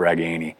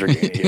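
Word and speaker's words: Ragaini: talking about Nico Ragani's family and learning the proper pronunciation of Ragani, those are the things I'm really thankful for. Maybe he should learn it. – He still says Regania. Yeah. That's a tough habit to break.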